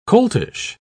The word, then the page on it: coltish: British English